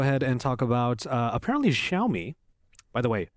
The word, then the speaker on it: Xiaomi: She gave me, she's like an expert on how to pronounce it, so Xiaomi.